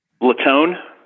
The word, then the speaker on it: Latone: Latone.